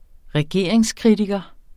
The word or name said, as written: regeringskritiker